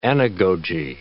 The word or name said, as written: anagoge